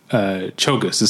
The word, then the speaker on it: Czolgosz: Chogos is how it's pronounced. It's spelled. Okay. It's like C-Z-O-L-G-O-S-Z, something like that.